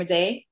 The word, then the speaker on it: Madej: You know who Dr. Carrie Madej? – No. – Oh my God. – No, Brett, no. Oh my God. Oh, she's amazing. Her last name is spelled M-A-D-E-J. Carrie Madej. C-A-R-R-I-E.